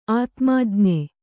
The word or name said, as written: ಆತ್ಮಜ್ಞ